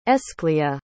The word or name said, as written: aescleah